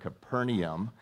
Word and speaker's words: Capernaum: That's the proper way to pronounce that word, Capernaum.